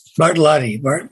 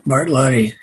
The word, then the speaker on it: Bartlotti: And we have Len Bartoloni. Is that how you say it, Len? – Bartolotti. – Bartolotti, yeah, with us.